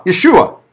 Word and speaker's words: Yeshua: That Jesus is, by the way, if you were to pronounce Jesus in Hebrew, what would you be saying? Joshua. Yeshua. Yeah. That's all it was. Jesus is just the Greek pronunciation of the name Joshua.